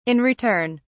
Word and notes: in return: British English

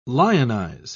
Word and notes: lionise: British English

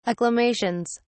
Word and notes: acclamations: British English

